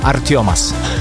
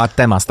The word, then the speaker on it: Artemas: Artiomas. – Wow, naprawdę. – No, tak się wymawia, wiesz, a ty byś powiedział Artemas, tak? – Tak, powiedział, no.